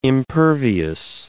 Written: impervious

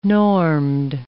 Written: normed

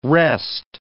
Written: rest

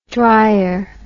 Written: dreyer

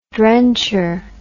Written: drencher

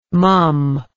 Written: mumm

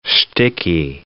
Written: shticky

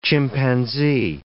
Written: chimpanzee

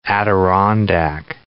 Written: Adirondack chair

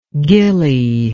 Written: ghillie

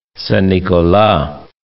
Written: Saint-Nicolas

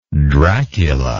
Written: dracula

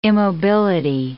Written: immobility

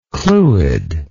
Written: clwyd

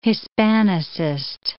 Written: Hispanicist

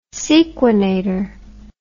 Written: sequenator

